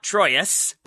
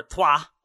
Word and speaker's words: Troyes: Let's keep you focused on Americans by talking about Erik Palmer Brown, the 24 year old center back for not Troyes, but for Troyes. I have now learned from my French pronunciation.